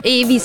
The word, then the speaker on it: Ave: anche vi con Meet Me in the Dark, in realtà è italiano, quindi Ave, direi proprio così, che la pronuncia è legata estremamente a come è scritto il nome Ave, o Evi se vogliamo dirlo insomma all'americana o all'inglese.